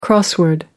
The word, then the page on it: crossword: American English